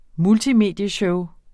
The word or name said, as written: multimedieshow